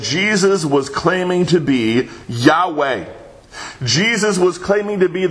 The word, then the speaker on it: Yahweh: And that Hebrew verb is pronounced Yahweh.